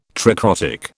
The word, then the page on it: tricrotic: British English